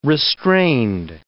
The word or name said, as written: restrained